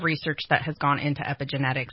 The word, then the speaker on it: epigenetics: It's called epigenetics. Did I pronounce it right? – Yeah, great job.